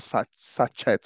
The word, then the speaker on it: sachet: For instance, I give you an example, a word, sachet, S-A-C-H-E-T, yeah. – Right? Pronounced sachet, right, in British English. But Nigerians, there's this popular water called sachet water. Or Nigerians say sachet, right? Yeah. Because the sounds, all the letters are pronounced, no silent letter there.